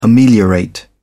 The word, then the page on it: ameliorate: British English